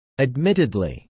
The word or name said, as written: admittedly